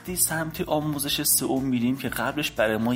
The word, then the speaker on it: SEO: در زبان فارسی SEO رو سئو تلفظ میکنن